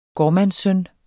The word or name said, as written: gårdmandssøn